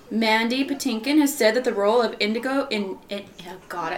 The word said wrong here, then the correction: Inigo: It's not Indigo. – It's Inigo. – Inigo Montoya.